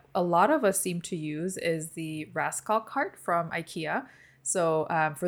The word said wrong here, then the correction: Raskog: those of you who are not familiar, that's spelled R-A-S-K-O-G, Raskog card.